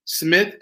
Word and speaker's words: Smith: So it's Maurice, M-A-U-R-I-C-E. Smith, S-M-I-T-H, music with a Q. M-U-S-C-I-Q.